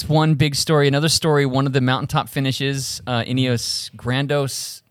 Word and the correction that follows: Grenadiers: Grenadiers. – Yeah, I butchered it.